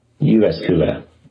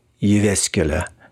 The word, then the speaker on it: Jyväskylä: Give me a moment while I check the pronunciation on YouTube. Jyväskylä. University of Jyväskylä, maybe who have launched an online survey.